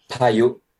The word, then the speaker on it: Paiyu: Paiyu? Is that how you pronounce it? – Yeah, Paiyu. Paiyu, it means storm.